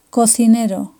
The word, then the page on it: cocinero: Spain